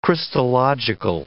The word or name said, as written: Christological